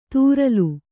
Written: ತೂರಲು

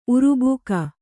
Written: ಉರುಬೇಗ

